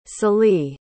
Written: sallee

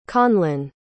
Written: conlan

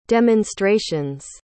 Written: demonstrations

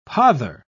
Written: pother

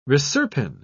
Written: reserpine

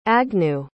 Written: agnew

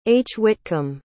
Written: hwitcomb

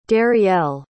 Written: darielle

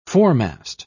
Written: foremast

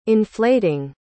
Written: inflating

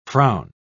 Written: frown

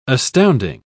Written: astounding

